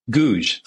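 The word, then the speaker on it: Gouges: So Pinot Gouage, is that how you say it? – Pinot Gouge. – Gouge. I'm sorry.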